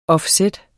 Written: offset

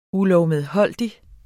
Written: ULOVMEDHOLDIGT

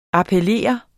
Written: appellere

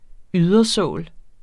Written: ydersål

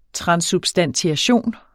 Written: TRANSSUBSTANTIATION

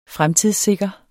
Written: fremtidssikre

